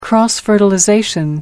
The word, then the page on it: cross fertilization: American English